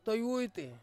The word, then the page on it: toiwuiti: Ansus